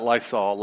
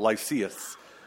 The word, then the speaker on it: Lysias: I'm just going to call him the commander, but is it Lysias? Okay. Not Lysol, Lysias.